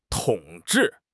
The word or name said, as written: 统治